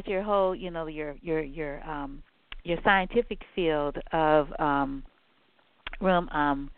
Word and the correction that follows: rheumatology: Is that how you pronounce it? – Rheumatology.